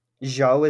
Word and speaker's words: Zhou: Zhou is how you say his last name? – Yes, Guan Yu Zhou.